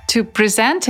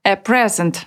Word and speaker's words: present: to present — это глагол, который означает «дарить». Есть и такое же существительное, но только с ударением на первый слог. A present — подарок.